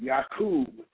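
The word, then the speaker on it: Yakub: That's Y-A-K-U-B. Some spell it Y-A-C-U-B. But it's Y-A-K-U-B. Yaqub. Okay, and it's not a joke, it's for real. Check it out. Yaqub, the big-headed scientist.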